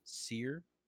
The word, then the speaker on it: Cyr: Sear. Yeah, it's spelled C Y R.